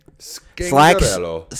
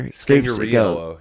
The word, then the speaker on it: Scangarello: Scangarello. – Sachs gave something else. – What? Sachs gave? – Slacks gave some, one pronunciation. – Scangarello. – And then Mark gets up there. – Scangariello.